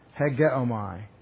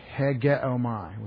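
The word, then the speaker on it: hegeomai (ἡγέομαι): When he says the leader, he uses a verb, hegeomai. Hegeomai. H-E-G. That's the H, the little rough breathing. H-E-G-O-M-A-I. Hegeomai, with a long E.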